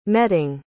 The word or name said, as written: meting